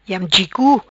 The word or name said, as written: Iam Chi Cu